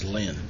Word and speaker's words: lien: Is it Lynn or lien? Professor Byron Davis from South Texas College of Law said it's lien. And I hear everyone pronounce it lien. – I've always called it lien, but I think probably both are correct.